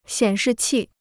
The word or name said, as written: 显示器